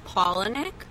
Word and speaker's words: Palahniuk: And his last name is pronounced Palahniuk, not Palahniuk. – Or Patchikuk. – It's not Patchikuk. It's Palahniuk. So, exactly.